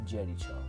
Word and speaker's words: Jericho: Durante este mismo año, el entonces cuarteto grabó y lanzó de nuevo junto a Harris Jones su primer álbum titulado Walls of Jericho se pronuncia Jericho, pero se escribe Jericho con J.